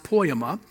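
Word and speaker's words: poiema: As I said, it's a Greek word. It's pronounced poema, and it's used only two times in the entire Bible.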